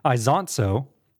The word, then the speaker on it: Isonzo: Anyways, Isonzo is how it's pronounced, like Forza with a zont noise, I guess.